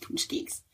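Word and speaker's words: pączki: But punchkis, which is funny because if you look at it, it does not look like it should be pronounced that way. But since coming to Chicago, I have learned it is pronounced punchkis.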